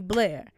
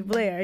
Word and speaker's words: Blair: And I wanted one syllable. So, like, Imani Blair, Imani Blair.